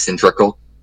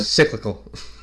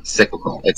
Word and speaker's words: cyclical: Like you said, you know, it's cyclical. You know, definitely mispronounced that word. It's all good. – Cyclical. – Thank you. Cyclical.